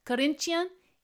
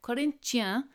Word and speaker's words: Corinthians: And you would never be able to tell it from the pronunciation, but Corinthians is Corinthians. They're named after Corinthians, like in the New Testament.